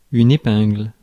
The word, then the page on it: épingle: /e.pɛ̃gl/